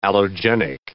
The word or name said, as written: allogenic